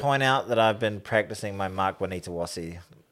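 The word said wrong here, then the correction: Nawaqanitawase: I think you're missing a syllable or two there. – Oh, shit. Mark Nawanitawasi. – Yes, there you go.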